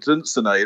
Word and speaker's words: Dunsany: He comes from the family of Dunsany, I think.